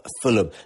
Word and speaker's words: Fulham: it's hard for me to mention the word. Fulham, that's it, that's how you say it. Fulham days as well.